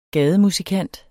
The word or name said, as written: gademusikant